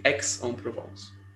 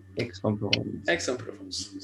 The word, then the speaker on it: Aix-en-Provence: And I checked the pronunciation, and it's actually Aix-en-Provence. – Aix-en-Provence. – So yeah, I thought that the first three letters are gonna be pronounced differently, but it turns out it's Aix-en-Provence.